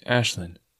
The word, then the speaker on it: Aislinn: It's spelled A-I-S-L-I-N-N, but you say it like Ashlyn.